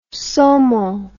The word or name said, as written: saumon